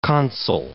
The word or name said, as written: consul